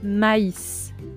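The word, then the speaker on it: maïs: Por ejemplo, M-A-I-S. La palabra me se pronuncia a i i e, pero si le pongo trema sobre la i se pronuncia maíz, maíz como el maíz en español, ¿no?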